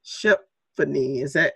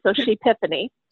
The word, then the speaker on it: Shepfany: She is the founder and director of Shepfany. Am I saying that correctly? – It's like epiphany, but she had an epiphany, Shepfany.